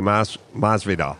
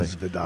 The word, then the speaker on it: Masvidal: Oh, Jorge Masvidal. – Yes, right. Obviously. – Masvidal.